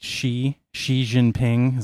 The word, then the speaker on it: Xi Jinping: Is it Xi Jinping? Is that how you pronounce his name? – Oh, yeah.